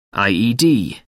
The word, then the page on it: ied: American English